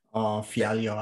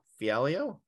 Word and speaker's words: Fialho: So now we're talking about a welterweight fight between Michelle Pereira versus Andre, I'm going to butcher this, Falho? – Fialho, I would say. – Fialho? – Okay.